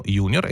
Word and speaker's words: junior: Sì, junior è una parola assolutamente utilizzata anche in italiano, con la pronuncia giusta dell'italiano e quindi del latino, junior e senior. Quindi più o meno gli antichi romani le pronunciavano così.